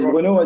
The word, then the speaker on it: Bruno: So my name is Bruno, as you said. A couple of people pronounce my name with an Italian accent, but I'm actually French.